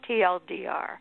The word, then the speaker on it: TLDR: Which is not easy to pronounce. You just have to say the letters, TLDR.